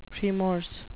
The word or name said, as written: premorse